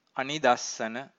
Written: anidassana

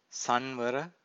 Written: sanvara

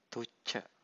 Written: thucca

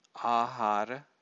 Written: ahara

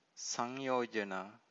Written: sanyojana

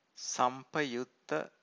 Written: sampayutta

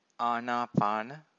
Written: anapana

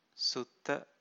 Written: sutta

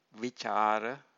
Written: vicara

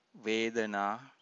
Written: vedana

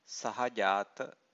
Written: sahajata

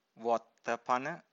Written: vottapana

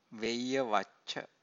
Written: veyyavacca

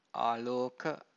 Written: aloka

